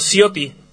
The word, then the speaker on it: Ciotti: ¿Es correcta la pronunciación, Ciotti? – ¿Sí? Sí, a mí mismo, Ciotti. – Ya, perfecto.